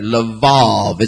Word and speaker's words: Levav: L-E-V-A-V. V as in Victor. L-E-V as in Victor. A Apple V Victor, Levav. It's pronounced Levav.